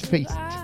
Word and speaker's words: Feist: Ella es Leslie Feist, mejor conocida solo como Feist o Feist, depende cómo lo pronuncie, ¿no? Pero bueno, no es Fey, es Feist.